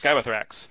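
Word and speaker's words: Scabeiathrax: He too brought Mortarion, but he also included Scabalex. I hope I'm pronouncing that right. He's the Forge World Great Uncleed One character in this list as well. Three malefic Scabathrax. – Skybathrax. There we go.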